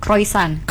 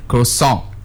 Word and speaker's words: croissant: We usually just mispronounce this one. Like seriously, almost all of us ya. Croissant. – we always say like, I want one croissant. – I want croissant. – It's not sun. It's like you have to increase the pitch.